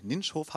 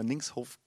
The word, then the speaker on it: Nincshof: Ninschhof, habe ich gehört, spricht man das aus. Ich habe auch immer Linkshof gesagt.